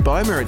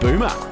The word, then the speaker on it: Bohmer: I found out by our mate Duncan, who works for Injuna Beats, that this next remixer, his name is actually not Ben Bohmer. It's pronounced Ben Boomer. – Really? – Yes. – What? – There's no H. You don't pronounce it, so it's Ben Boomer, like, you know, schooner.